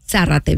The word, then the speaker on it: Zárate: Es con tilde, bueno, en español es con tilde la A, entonces Zárate. – Zárate, ¿viste? – Gracias.